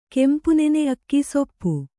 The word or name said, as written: ಕೆಂಪುನೆನೆ ಅಕ್ಕಿ ಸೊಪ್ಪು